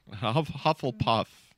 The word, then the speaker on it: Hufflepuff: You're going to put Hermione in fucking Hufflepuff? That's insane. Did I say that right? Is it Hufflepuff or Hufflepuff? – Hufflepuff. – Hufflepuff. That makes more sense.